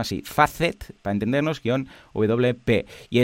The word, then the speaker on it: Facet: Es ah, Facet, Facet, e F-A-C-E-T, Facet W-P, o WP, como lo quieras llamar.